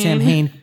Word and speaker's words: Samhain: And it also involves, as part of the plot, now it's spelled S-A-M-H-A-I-N. – Yeah. Samhain. – Samhain. But it's pronounced Samhain, I think. – I can't. – It's Celtic.